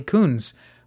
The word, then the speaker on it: Kunz: So did our next band leader, Charlie Coons, who was born in Allentown, Pennsylvania in 1896.